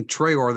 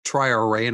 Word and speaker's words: Traoré: Boubacar Traoré, I've been saying Traoré this whole time, and I see Notre Dame's pronunciation is Traoré, and I think it's going to take us all a little bit of time to learn that and commit that to memory.